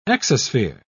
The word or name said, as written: exosphere